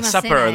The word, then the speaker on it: Supper: supper, The Last Supper. The Last Supper, exactamente. – Con una P es Super, con dos P es Supper.